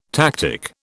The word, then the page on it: tactic: British English